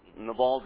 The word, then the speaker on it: Nabal: You will remember that David asked help from Nabal, as you're used to probably pronouncing it. Nabal is how you would say it in Hebrew. And Nabal does not give his consent.